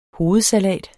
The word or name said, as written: hovedsalat